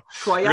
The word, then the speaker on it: Troyes: like Troyes, I guess that's how you pronounce it. Troyes. – there you go. Troyes. Really, really Midwest it up.